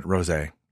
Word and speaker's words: rosé: Yeah, it's this gorgeous rose color. – Yeah, I believe they pronounce it rosé, Jamie.